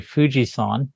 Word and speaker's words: Fujisan: Yeah, so the other team is in Shizuoka, and it's called Hayate Fujisan, although it's spelled 223. So it's the Japanese onyomi, the sound of the numerals 223 is pronounced Fuji-san, like Mount Fuji.